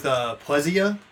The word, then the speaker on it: Plezia: By the way, what is the correct pronunciation of your last name? Is it Plezia? Is it Plezia? – Well, you know, like after it was like Americanized, I usually go with Plezia.